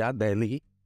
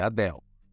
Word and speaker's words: Adele: Nós brasileiros chamamos ela de Adele. Adele. Mas é Adele, tá? Adele. O L em inglês ele é pronunciado, não é como o nosso que tem som de U. Então o L é L, tá? Então Adele.